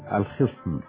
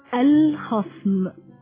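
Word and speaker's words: الخَصم: كثير من الناس ينطقون كلمة الخصم بكسر الخاء، وهذا خطأ، والصواب فتحها فتنطق هكذا: الخصم.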